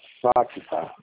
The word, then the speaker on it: Facta: Facta. Vou ver aqui, tá. Vou ver aqui. – Pera aí, eu vou até entrar no sistema. Ah, o Facta ele é FACTA. O Tá. – C é mudo.